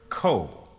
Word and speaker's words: Ko: And it was said that every time this African would point to this instrument with his daughter in tow, he would say to her, Ko, as if it was spelled K-O, a single syllable.